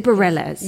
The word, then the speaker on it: Bareilles: Is it Bareilles? – You said it right.